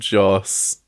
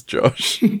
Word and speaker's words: Joss: the name Joss was specifically designed for people to mispronounce as Josh.